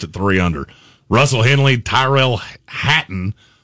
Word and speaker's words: Tyrell Hatton: I don't know why I've said, I've heard Brian call him Tyler Hatton for so long that now I can't say his name Tyrell. – Right. Tyrell Hatton.